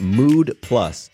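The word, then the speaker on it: Müd: The company is called Mood Plus, but it's spelled M-U-D with an umlaut over the U.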